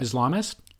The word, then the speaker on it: Islamist: And gives you all the backstory into how radical Islamicist, did I say that right? – Islamist? – Radical Islamist. The radical Islamist movement took root starting in the 1930s.